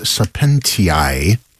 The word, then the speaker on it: Sapientiae: O Virtus Sapientiae. That's my memory of how an A-E is supposed to be pronounced in Latin from private school.